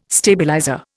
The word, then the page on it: stabilizer: Indian